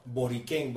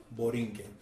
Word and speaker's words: Borinquen: So they used to pronounce it Borinquen, but through the years it has been changed to Borinquen.